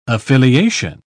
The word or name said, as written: affiliation